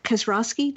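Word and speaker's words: Kaczorowski: Peter Kazrowski, did I say that right? – It sounds right. Yeah, I think so.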